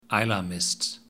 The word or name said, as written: Islay Mist